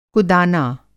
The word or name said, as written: कुदाना